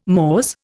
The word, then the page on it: mores: Indian